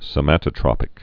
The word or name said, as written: somatotropic hormone